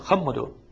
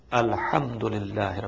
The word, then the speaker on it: Alhamdulillah: It's not Khamudu. It is Alhamdulillah Rabbil Alameen. You know, sometimes we need to know that what is the proper place of pronunciation, where I have to pronounce Ha and where I have to pronounce Kha.